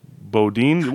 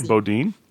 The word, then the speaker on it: boudin: Disney films, Kool-Aid, Bodine. What is that word? B-O-U-D-I-N. – Oh, that's a sausage, a kind of sausage. Okay. – Do I say it right? Boudin? – Yeah, Boudin. – Boudin, okay.